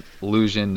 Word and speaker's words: LLusion: It's not illusion. Nothing like that. It's spelled LLusion, no I, all that good shit.